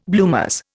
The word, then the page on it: bloomers: Indian